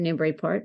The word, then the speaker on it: Newburyport: I have written two books set in my actual town, Newburyport, which you pronounced correctly.